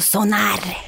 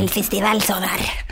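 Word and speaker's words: Sónar: Du uttalar det som norskt bara. Mm. – Den kunde... Sonar. Mm. El festival Sonar.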